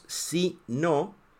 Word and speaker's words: si no: Y la pronunciación es diferente. Sí, no.